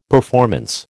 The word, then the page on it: performance: American English